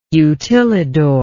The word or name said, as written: utilidor